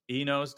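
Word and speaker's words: INEOS: Inos.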